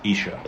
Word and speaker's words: Esher: I believe it's pronounced Esher.